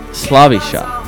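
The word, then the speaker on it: Slaviša: Well, the Karen turns that S into the English equivalent of SH. Which makes his... Yeah, that's exactly right. – Shush. – Shoot. – Shush. – This makes his first name Slavisha.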